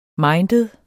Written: minded

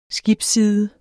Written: skibsside

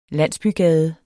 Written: landsbygade

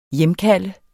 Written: hjemkalde

